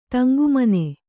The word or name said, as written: ತಂಗುಮನೆ